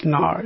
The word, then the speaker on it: ngar: In Tibetan, this energy is called Nar. N-G-A-R. It's pronounced Nar.